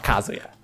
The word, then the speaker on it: Kazuya: Não esperava ver Kazuya no Smash. É, Kazuya no Smash, né? Desculpa a minha pronúncia, da última vez o Chapéu, antes de começar a gravar, ele tinha falado pra eu falar Kazuya.